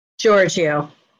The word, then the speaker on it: Giorgio: Giorgio.